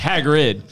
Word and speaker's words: Hagrid: It's Hagrid. – Hagrid. – Just Hagrid.